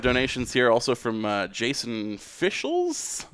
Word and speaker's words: Fischels: F-I-S-C-H-E-L-S. So I think I had it right. – Yeah, Fischels. Yeah, it's got to be right. It's like two of Boy Meets World star Danielle.